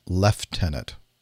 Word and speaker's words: lieutenant: Why is it that you guys pronounce lieutenant leftenant? I don't see an F in there.